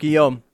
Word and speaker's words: Guillaume: And also, I'm trying to think of like the other actor who is, is it Robert Guillaume? Eesh. I can't. – Oh, Robert Guillaume.